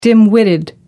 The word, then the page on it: dim-witted: American English